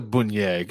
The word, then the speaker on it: beignet: It's a beignet? – Yep. – It is a beignet.